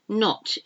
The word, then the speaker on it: knot: B-L-A-C-K-U-A-A-R-D. K-N. There's quite a few of those. K-N words. The K is silent in the word no. I don't know why it is, but it's not pronounced, K-N-O-W. Knot. If you want to tie a knot in something, it's K-N-O-T. So we've got knot, K-N-O-T, and we've got knot without the K.